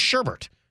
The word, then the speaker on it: sherbet: By the way, did you know there's no R in sherbet? Second R, anyway? – Yeah. – Bizarre. The word is sherbet, and yet it's spelled S-H-E-R-B-E-T.